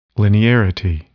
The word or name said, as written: linearity